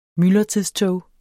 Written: myldretidstog

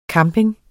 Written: camping

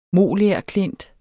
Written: MOLERKLINT

